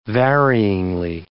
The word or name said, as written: varyingly